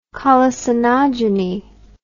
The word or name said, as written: colicinogeny